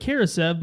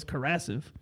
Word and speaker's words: Karasev: It's because it looks like Karasev, but I'm pretty sure when I listen to the games on League Pass, it's always Karasev.